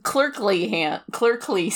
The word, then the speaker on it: clerkly: It's clerkly skill, which is like a horrible word to pronounce. – That's a little better. That there's an L in there.